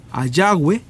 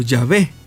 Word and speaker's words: Yahvé: En base a un estudio serio del lenguaje hebreo, la mayoría de los eruditos de la actualidad creen que el tetragrama probablemente se pronunciara o sonara como Ayahwe o Yahvé, como aparece en algunas traducciones.